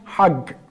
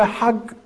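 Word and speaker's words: hag (חג): Hac, hag, İbranice'de. Aslında Sami bir kelimedir. Aramca'da, İbranice'de, Süryanice'de, Keldanice'de ve Arapça'da hemen hemen aynı yaklaşık telaffuzlarla kullanılan, yaklaşık manalara gelen bir kavramdır. İbranice'de hag, bayram manasına geldiği gibi hac manasına da kullanılır.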